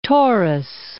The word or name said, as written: Torres Strait